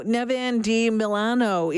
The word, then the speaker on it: Devin deMilliano: Am I saying your name right? – Yep, Devin deMilliano.